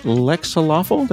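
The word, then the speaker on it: Lexaloffle: He created the Pico 8, living in Japan and working at Lexalawful. Did I pronounce that correctly? – Yep, that's right.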